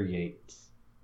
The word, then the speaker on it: Yeats: Mine is called The Second Coming, and it is by William Butler Yeats, mm-hmm. – which is spelled as though it rhymes with Keats, but it doesn't.